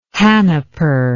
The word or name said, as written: hanaper